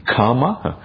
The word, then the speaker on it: kama: There's a slight different in pronunciation that is often missed by people who don't have an Indian background. And the kamma is a double M, and it's pronounced as if kamma, twice. Kama is a long A sound, and it's different.